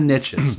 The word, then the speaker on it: niche: And well, you Americans call, I'm Canadian, if anybody's listening, you Americans call it, there's riches in the niches. Now, we pronounce it niche, so it doesn't rhyme.